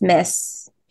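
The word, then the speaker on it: Myss: And Carolyn Mize, Miss, I never say her name right, but Carolyn Miss, yeah.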